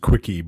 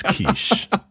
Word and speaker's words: quiche: It's an egg pie, and it looks like it's pronounced quickie, but it's not. It's quiche. If you see a word that looks like quickie, don't call it quickie, because you will be laughed at.